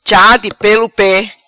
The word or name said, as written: Cha Di Pelo Pe